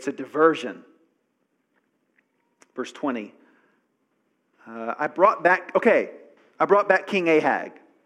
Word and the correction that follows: Agag: Agag. The G's are sometimes pronounced as H's. I brought back King Agag.